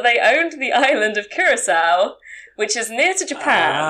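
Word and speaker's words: Curaçao: Which was in, and I don't know if I'm going to pronounce this correctly, Curacao. – As in Blue Curacao? – Curacao!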